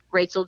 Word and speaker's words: Rachael: It's R-A-C-H-A-E-L. And from there, you can connect with me over on Facebook. I'm Rachel Joy Gilbert over on Facebook and Rachel J.